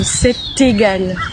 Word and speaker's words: c'est égal: Who or what would want me to experience it, But when the first letter is a while, you have to pronounce the last letter of the verb. So c'est égal. You have to pronounce it.